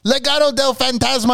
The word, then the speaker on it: Legado del Fantasma: Going against Mr. Malave's cousins, Legado del Fantasma.